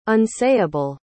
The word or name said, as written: unsayable